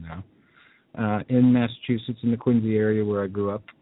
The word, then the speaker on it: Quincy: all the common people we know out there in Quincy, as we call it, Quincy, even though it's spelled with a C.